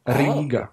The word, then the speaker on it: Riga: Yeah, but harder R. – Riga. – Riga. – You're supposed to say Riga. – Are you saying it like that to get us to pronounce it right? Where are you really supposed to hold that I sound that long?